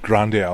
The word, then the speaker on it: Grande: Ariana Grande. Jawohl. – Es wird Grande ausgesprochen.